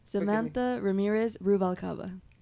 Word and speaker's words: Samantha Ramirez Ruvalcaba: I was trying so hard not to, but pronounce it for me. – Samantha Ramirez Ruvalcaba.